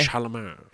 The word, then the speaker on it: Chalamet: Timothy Chalamet. – Chalamet.